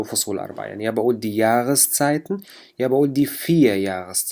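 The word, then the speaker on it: die Jahreszeiten: هون لما وصلت بكلمة تانية بدي ألفظ حرف الآ، فبقول دي ياغسزايتن، دي ياغسزايتن، وحرف الآ هون تلفظ مثل حرف الغين باللغة العربية.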